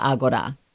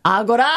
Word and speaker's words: Agora: It's called the marketplace, but we pronounce it in Greek as the Agora. – A what? – Agora.